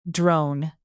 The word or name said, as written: drone